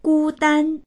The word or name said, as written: gu1dan1